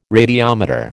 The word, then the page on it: radiometer: American English